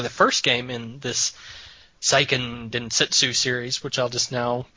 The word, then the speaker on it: Seiken Densetsu: Oh, it's Densetsu. I put an extra T in there. Seiken Densetsu. – Seiken Densetsu. So, pronunciation cast over.